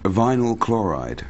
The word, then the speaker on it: vinyl chloride: Vinyl chloride. – Vinyl chloride.